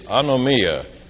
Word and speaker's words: anomia: The Greek word that John used is, if you'll forgive pronouncing the Greek, it's anomia, A-N-O-M-I-A.